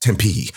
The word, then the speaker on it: Tempe: Oh, the third one was, it's pronounced Tempe.